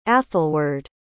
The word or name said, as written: athelward